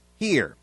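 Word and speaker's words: hir: In English, the most commonly used singular gender-neutral pronouns are Z, sometimes spelled Z-I-E, and H-I-R. Z is the subject pronoun and is pronounced Z, and here is the object and possessive pronoun and is pronounced here.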